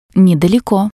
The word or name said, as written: nedaleko